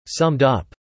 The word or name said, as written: summed up